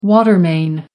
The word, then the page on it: water main: American English